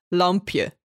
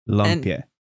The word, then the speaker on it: Lampje: In Dutch, it's Lampje. Lampje.